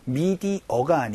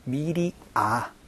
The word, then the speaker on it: media: 미디어가 아니고 미디아, 미디아, 아로 발음된다라는 거 조금 주의를 해주시면 좋을 것 같아요.